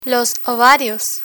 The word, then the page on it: ovarios: Spanish